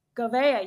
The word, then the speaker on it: Gouveia: Gouveia, yes.